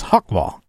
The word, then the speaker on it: Jaguar: which is talk about how I've developed the canonical pronunciation of Jaguar, and it's Hawkball, and how I've never really had a conversation with a flat earther. And, you know, is that really about atheism? I don't know. – Hakwa New Year! – Hakwa New Year! um, and so at some point, uh, the animal's still a jaguar, by the way. Uh, this is only the pronunciation for the car.